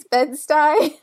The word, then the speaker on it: Bed-Stuy: I had to look up how to say that because I know I say it wrong sometimes. I think it's Bed-Stuy.